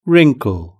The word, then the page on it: wrinkle: British English